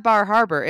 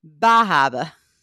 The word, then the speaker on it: Bar Harbor: Oh, my husband says it's not Bar Harbor. It's Bahaba.